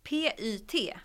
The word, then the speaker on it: PYT: Och nu har de ju kommit med sitt nya som är PYT. Alltså pytt. Fast jag tror att det uttalas lite mer som pyt.